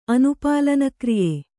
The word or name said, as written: ಅನುಪಾಲನಕ್ರಿಯ